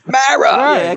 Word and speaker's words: Mara: It's Mara.